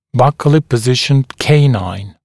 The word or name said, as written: buccally positioned canine